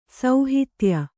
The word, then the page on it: ಸೌಹಿತ್ಯ: sauhitya